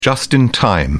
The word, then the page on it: just in time: British English